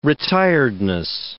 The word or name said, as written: retiredness